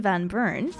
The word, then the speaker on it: Van Buren: And he's singing Martin Van Buren. Well, it's actually Martin Van Buren. Yeah, don't mind how I pronounce the last one. It's because of what he actually spoke first. I think he's actually German.